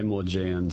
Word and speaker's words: Maugean: Now, am I saying it right, correctly? Is it Maugean? Is it Maugean? – Everyone says it differently. I say Maugean.